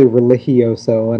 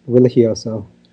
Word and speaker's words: Religioso: He was happy to find me on here, but it's actually Religioso, and I know everyone, you can put it's not, you know, phonetically, you're not doing it incorrect, but it's more Spanish, so it's Religioso.